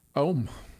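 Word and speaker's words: Ohme: We started off the three-song set with Medicina by, let me make sure I'm saying this right. Ohm. It's O-H-M-E. We're going to call it Ohm.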